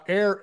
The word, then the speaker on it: Eir: And if people want to learn more, Air Inc., but it's spelled E-I-R. Thank you very much. Correct. E-I-R.I-N-C.